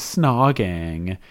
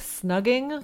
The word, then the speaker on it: snogging: Where they're like, I found out that kissing is snogging. – And then she doesn't know how to pronounce it because she keeps calling it like snugging.